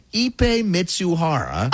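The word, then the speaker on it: Ippei Mizuhara: There's a phonetic pronunciation, Ipe Mitsuhara.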